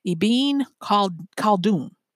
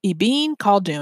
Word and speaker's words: Ibn Khaldun: Ibn Khaldun. And I'll spell that for you. I-B-N for Ibn Khaldun. K-H-A-L-D-U-N. And I know I'm not pronouncing that hard K like Khaldun, but you get the gist if you want to look him up.